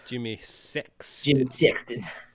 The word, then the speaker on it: Sexton: Jimmy Sexton. – Emphasis on the six, am I right? Ew. – No, you are not.